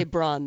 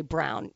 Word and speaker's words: Braun: You want to say Braun, though, because that's how it's spelled. – It's spelled, damn it. – It's always so hard for me to pull away from Braun and say Brown.